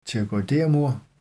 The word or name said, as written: pterygoidermur